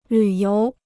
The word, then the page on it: 旅游: lǚ yóu